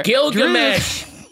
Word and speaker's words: Gilgamesh: Ajak offers to wipe Thena's memory, but you know, Glisham volunteers to look over her. – Gilgamesh. Glisham. Gilgamesh. – Gilgamesh volunteers to, honestly, it's spelt the way it's pronounced.